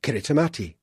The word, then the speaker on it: Kiritimati: The people of Kiribati, formerly the Gilbert Islands, have difficulty pronouncing the letter S, so Christmas becomes Kritmat, Kiritimati.